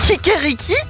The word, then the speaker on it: Kikeriki: En allemand, c'est kirekiki. Non, kirekriki. Waouh, ouais, bon, voilà. – J'aime bien les infos à moitié. Bon, bah voilà, le truc, non. J'arrive pas à le prononcer.